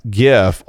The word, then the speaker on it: GIF: Apparently, that GIF, and yes, I said GIF, and you will take my hard G when you pry it from my cold, dead, soft palate, uh, is called Neon Cat, like, or Nyan Cat.